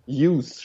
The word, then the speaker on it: Hughes: Hughes. Hughes spricht man es aus.